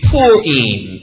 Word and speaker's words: Purim: And so the Jewish holiday or feast of Purim, again, which is taken from the Hebrew word pure, it is almost pronounced like our English word pure, but poor, which is where we get Purim.